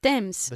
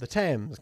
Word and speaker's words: Thames: ¿Cómo se dice Tamesis? – Thames. – The Thames, good.